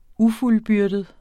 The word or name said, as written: ufuldbyrdet